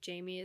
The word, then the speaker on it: Jaime: So my name, Jaime, is spelled like, if you're in Southern California, it's spelled like Jaime.